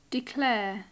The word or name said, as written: declare